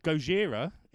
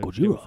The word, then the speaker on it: Gojira: Gojira is the correct pronunciation of Godzilla. – Ah, so Gojira.